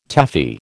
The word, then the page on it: Taffy: American English